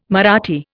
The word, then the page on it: Marathi: Indian